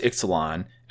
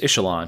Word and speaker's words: Ixalan: It is pronounced Ixalan. When it was spoiled, everyone was like, oh, it's Ixalan. And then a couple people are like, no, it's Ixalan, because the X is pronounced as a CH in the Mayan dialect.